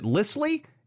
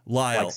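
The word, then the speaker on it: Lisle: Are you sure you're pronouncing it correctly? Because I'm looking at it, and it's L-I-S-L-E. You sure you got the pronunciation correct? – Yes. Okay. The S is silent, yeah.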